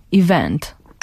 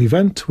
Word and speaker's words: event: Nie mówi się event, mówi się event. Pretend that the e doesn't exist, czyli takie event, event. – Krótko mówiąc, lekkie... akcent na drugą sylabę.